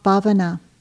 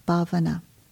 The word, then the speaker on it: Bhavana: The third pillar is called Bhavana, and I'll spell it B-H-A-V as in victory, A-N-A, Bhavana.